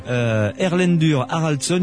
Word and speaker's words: Erlendur Haraldsson: Et une dernière chose, si vous êtes à Paris aux alentours du 16 avril pour cette conférence de Erlendur Haraldsson, une conférence, je l'ai bien prononcé Pierre, c'est ça. – Bon d'accord. Erlendur Haraldsson, une conférence sur la réincarnation.